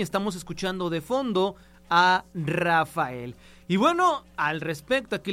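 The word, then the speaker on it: Raphael: Mencionarles que bueno, cuando usted busca a Rafael, sin duda, usted sabe que su nombre se escribe con PH, aunque se menciona como Rafael, pero no siempre fue así.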